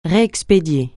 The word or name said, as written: réexpédier